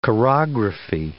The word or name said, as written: chorography